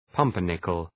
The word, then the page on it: pumpernickel: {'pʌmpər,nıkəl}